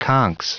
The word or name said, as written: conchs